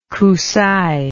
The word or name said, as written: Kusaie